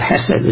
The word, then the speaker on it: hesed: The Hebrew word is the Hebrew word hesed, or kesed, depending how you pronounce it, but hesed is a more near pronunciation.